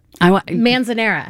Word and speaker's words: Manzanera: Manzanera.